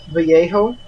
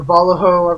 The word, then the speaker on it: Vallejo: I pronounce it Vallejo. – Vallejo. – Yeah. Or Vallejo, I've heard. It's V-A-L-L-E-J-O or something like that.